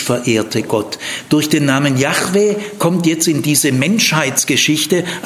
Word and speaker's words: Yahweh: Das Wahrscheinlichste ist Yahweh. Ton auf der zweiten Silbe, Yahweh.